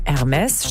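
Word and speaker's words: Hermès: Also bei Hermes steht da nicht H-E-R-M-E-S, sondern Englisch R und dann noch für Unordnung Mess, ne? Luft und Unordnung quasi. Das steht dann groß auf einer Handtasche mit dem hellgrünen Duolingo-Farbdesign.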